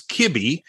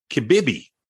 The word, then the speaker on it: Kibibi: I was convinced It was Kibby. And now I see you've written it's Kibibi.